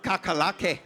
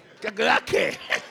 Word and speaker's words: Kakerlake: Kakerlake. – Are you serious? Is he saying the right thing? – Ja, ja, sagt er das Richtige. – What do you say? How do you say? – Kakerlake.